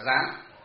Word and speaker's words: gián: Bất dung hào phân, bất thiện gián tạp, chữ gian ở đây đọc là gián.